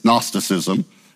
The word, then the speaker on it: Gnosticism: Now, we say agnostic is the way we divide the word, but the G actually goes with Gnostic. It comes from a Greek word. The G is silent. It's Gnosticism. It means knowledge.